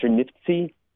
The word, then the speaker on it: Chernivtsi: It's a city uh, now what's called Chernivtsi. At the time, the Jewish population called it Chernivish, which is the, which is the, the, Yiddish pronunciation.